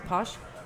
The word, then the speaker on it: pox: It's actually spelled P-O-X, Posh.